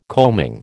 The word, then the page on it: combing: American English